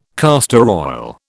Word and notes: castor oil: British English

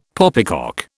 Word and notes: poppycock: British English

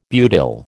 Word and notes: butyl: American English

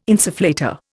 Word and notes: insufflator: Indian

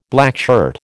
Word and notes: blackshirt: American English

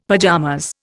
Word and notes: pajamas: Indian

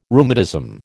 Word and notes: rheumatism: American English

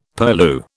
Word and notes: purlieu: British English

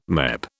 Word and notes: MEP: British English

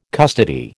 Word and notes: custody: American English

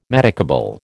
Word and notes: medicable: American English

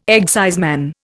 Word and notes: exciseman: Indian

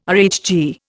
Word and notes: RHG: Indian

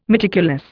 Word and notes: meticulous: Indian

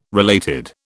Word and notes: related: British English

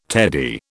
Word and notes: teddy: British English

